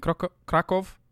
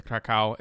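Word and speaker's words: Kraków: I'm sorry, it's Krakow. – Well, they pronounce it Krakow. Okay. But a lot of people say Krakow.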